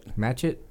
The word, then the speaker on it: Matchett: Cameron Machet? How do you say that? Machet? – It looks like Matchett. – Matchett?